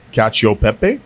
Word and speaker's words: cacio pepe: So it's called cacio pepe. – Yeah. – Did I say that right? – Yeah. Cheese and pepper.